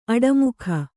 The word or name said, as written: ಆಡುಮೊಗ